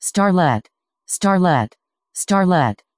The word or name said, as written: starlette